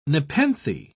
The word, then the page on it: nepenthe: British English